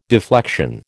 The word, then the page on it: deflection: American English